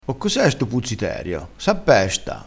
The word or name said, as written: puzziterio